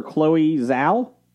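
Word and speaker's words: Chloé Zhao: Reason is director Chloe Zhao.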